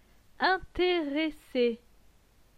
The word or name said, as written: Interesse